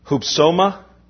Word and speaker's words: Hupsoma: Hupsoma is the word. H-U-P-S. Long O-M-A.